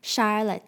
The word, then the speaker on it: Charlotte: 需要更正一下资讯，就是我在最后剪辑的时候发现，我把夏绿蒂这个英文名字讲成 Charlotte，但它其实应该是 S H 的发音哦，是 Charlotte 这样子，所以大家之后在念的时候不要像我一样搞混了。